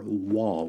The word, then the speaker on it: waw: This is the sixth 8-verse stanza of the psalm, and it begins with the Hebrew letter waw. We would spell that W-A-W in English.